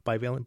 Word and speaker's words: bivalent: I want to ask a question about these boosters, because there's this booster, and correct me if I'm pronouncing it differently. It is bivalent booster, mm-hmm, right? – That's correct.